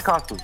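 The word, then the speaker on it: Karsten: Ich heiße Carsten.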